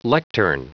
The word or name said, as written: lectern